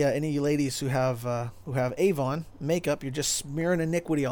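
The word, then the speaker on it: avon (עָוֹן): And in Hebrew, that word is avon. That's actually spelled like Avon, right? A-V-O-N.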